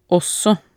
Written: ogsaa

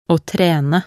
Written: å trene

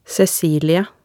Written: Cecilie

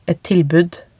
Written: et tilbud

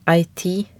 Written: ei tid

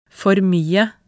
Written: for mye

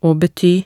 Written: å bety

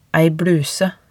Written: ei bluse

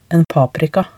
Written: en paprika

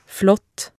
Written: flott

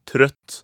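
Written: trøtt

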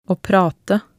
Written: å prate

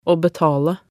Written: å betale

